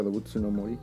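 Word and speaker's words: Dobutsu no Mori (どうぶつの森): No, pero la, pronunciación está más que perfecta. Dobutsu no Mori.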